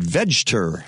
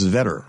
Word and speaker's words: Vegter: I would have said Vegter. It's Vetter. Okay. – I think so. – V-E-G-T-E-R.